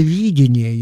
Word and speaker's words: видение: От того, что это пророческое видение, я подчеркну ударение на первом слоге, не календарно, оно не теряет ни своей точности, ни своей аккуратности.